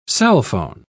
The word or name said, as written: cellphone